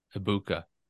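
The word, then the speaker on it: Egbuka: Yeah, the G is silent. – Ejbuka.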